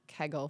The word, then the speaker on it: Kegel: Yeah, it's Kegel. spelled and for but some reason we, we kind of pronounce it or I've always kind of pronounced it like kegel, like beer keg, like kegel.